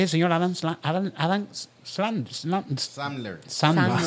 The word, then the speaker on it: Sandler: Iba Yo, el de Yauco diciéndole de calle cómo se pronuncia. Sandler, mijo, Sandler.